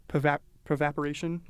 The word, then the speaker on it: pervaporation: And then once it passes through that, it then can go through this pervaporation process. Did I say that right? Pervaporation? – Yeah. Yeah. So it passes through there,